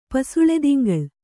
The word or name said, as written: ಪಸುಳೆದಿಂಗಳ್